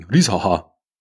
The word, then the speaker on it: Risacher: Muss bei Risacher musst du übrigens das CH eigentlich eher so entweder wie so den berühmten Glottal Stop, so heißt es im Englischen, wenn du sagst Butter zum Beispiel, statt Butter. Oder einfach wie ein H aussprechen. Risacher. Nicht, sondern so.